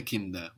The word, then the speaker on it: Kinder: But when we put the ER for the plural, actually, this D is not on the end of the syllable anymore. It becomes the first of the next syllable, kinder. So it softens, kinder. It becomes a new syllable, kinder, kinder. – Kinder.